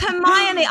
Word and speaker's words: Hermione: So there's Hermione. I wouldn't have known. – Hermione.